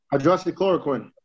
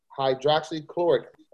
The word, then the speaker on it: Hydroxychloroquine: Hydroxychloroquine. – Hydroxychloroquine.